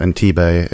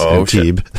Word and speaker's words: Antibes: I've been saying it wrong, too, this whole time. It's not Antibes, it's Antibes.